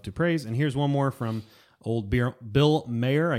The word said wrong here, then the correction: Maher: I guess Bill Meyer. Is that how you say it? – Bill Maher.